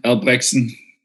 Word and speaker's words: Albrechtsen: My name is Jonathan Albrechtsen. Difficult uh, last name. Probably the worst combo is like a German name and then a Danish pronunciation.